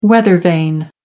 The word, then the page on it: weathervane: American English